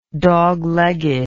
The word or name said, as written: doglegged